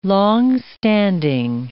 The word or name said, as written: long-standing